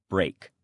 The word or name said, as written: break